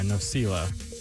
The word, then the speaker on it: Nosila: It's Nosila. – Yeah, Nosila.